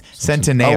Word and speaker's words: Centineo: No, its name is pronounced Centineo. – Oh, wait. – Not Centineo. – Yeah, because he's Italian.